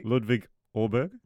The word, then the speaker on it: Ludvig Åberg: Say his name properly. – Ludvig Åberg. Is that correct? – Yeah, that's good enough for me.